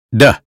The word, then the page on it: day: American English